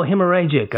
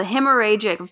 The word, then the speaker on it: hemorrhagic: Oh, hemorrhagic. Okay. – Yeah, the hemorrhagic fever viruses like Ebola and the Marburg virus.